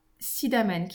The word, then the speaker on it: Sidemen: On est parti ensuite à Sidamen, qui s'écrit Sidemen. Euh, alors Sidemen, on est resté trois nuits.